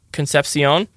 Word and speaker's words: Concepcion: He put Conception. – Well, that's how it's spelled. – Oh, sorry. Oh, yeah, KC Conception. Yeah. – Yes, that's how it's spelled, but it's not pronounced that way.